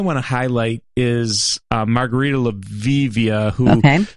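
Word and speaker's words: Levieva: And I think they're saying it's pronounced Laviva, which is fantastic.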